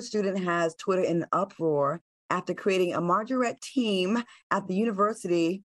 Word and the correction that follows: majorette: And Majorette.